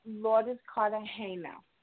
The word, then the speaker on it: Lourdes Cartagena: Hi, this is Lourdes Cartagena.